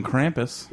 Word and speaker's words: Krampus: Well, if you believe in Santa, you got to believe in Krampus. – Right, exactly. Which is metal as fuck. – Did I say it right? – Krampus. – Yeah, I got it.